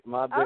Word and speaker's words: Mobjack: Is it Mobjack Tribe? – That's right, Mobjack okay.